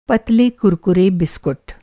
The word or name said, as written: कुरकुरा